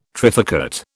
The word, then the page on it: trifurcate: British English